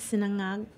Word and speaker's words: Sinangag: Sinangag. Say it again. I cannot get that ng in.